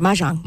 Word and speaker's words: Mahjong: Did I say that right? – Good. Mahjong.